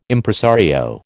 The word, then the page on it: Impresario: American English